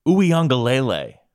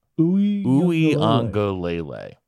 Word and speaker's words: Uiagalelei: Uh, Clemson needing a second half comeback uh, behind the arm of DJ Uiagalelei, which I learned how to pronounce correctly this weekend. – Uiagalelei. – Uiagalelei. – There you go.